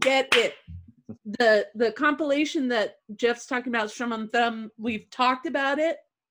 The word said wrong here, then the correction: Strum and Thrum: Did I just butcher the name? – Strum and Thrum. – Strum and Thrum.